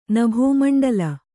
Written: ನಭೋಮಂಡಲ

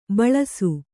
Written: ಭುಲ್ಲೈಸು

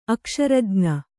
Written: ಅಕ್ಷರಜ್ಞ

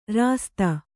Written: ರಾಸ್ತಿ

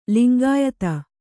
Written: ಲಿಂಗಾಯಿತ

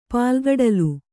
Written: ಪಾಲ್ಗಡಲು

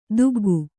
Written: ತೆಗ್ಗು

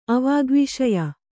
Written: ಅವಾಗ್ವಿಷಯ

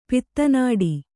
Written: ಪಿತ್ತನಾಡಿ

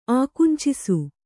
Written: ಆಕುಂಚಿಸು

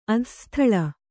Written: ಅಧಃಸ್ಥಳ

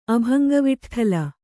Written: ಅಭಂಗವಿಠ್ಠಲ

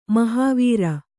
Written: ಮಹಾವೀರ

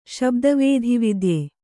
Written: ಶಬ್ದವೇಧಿವಿದ್ಯೆ

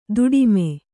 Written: ದುಡುಮಿ

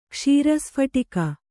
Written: ಕ್ಷೀರಸ್ಫಟಿಕ

